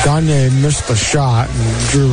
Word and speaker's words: Gaynier: Spells her name G-A-Y-N-I-E-R, but they say it's pronounced Gagne.